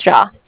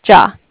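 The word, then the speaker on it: Jia: Oh, my name is Jha. Jha. – Okay. How do you spell that? – It's J-I-A.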